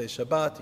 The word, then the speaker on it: Shabbat: You could say Shabbat, you could say Shabbos, whatever you like, but I'm just going to go with Shabbos, it makes it easier for me.